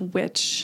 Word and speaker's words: wicce: I mean, literally etymologically, like the root word of witch is it's like a Nordic word, maybe Welsh, W-I-C-C-E, which is pronounced. It looks like Weiss, but it's pronounced witch, and it means wisdom.